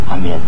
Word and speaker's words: амен: Что значит амен? Амен — это слово как бы святое, правильно? Оно произносится после благословения. Вообще-то надо сказать амен.